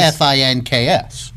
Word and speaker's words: Phynx: Yeah. That's Phynx. – But pronounced what is, Finks.